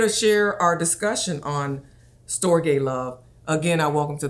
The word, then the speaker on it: storge: We're talking today about storge love. Rhymes with agape love, S-T-R-O-G-E.